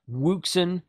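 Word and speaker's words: Wouxun: Now, it's spelled Woxun, something like that. Wuxin is how Richard at Maiden Trading Company says it.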